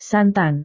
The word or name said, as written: santan